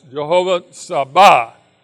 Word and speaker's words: Jehovah Sabaoth: The emphasis is on the second half. So it's Jehovah Sabaoth.